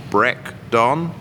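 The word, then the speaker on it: Breac Donn: So wait a minute, it's spelled Brecdonn, and you pronounce it Breakdon? – Not Irish. – Yeah, that's correct. So it's a Gaelic term, pronounced Breakdon, and it mean in Gaelic it translates to brown trout.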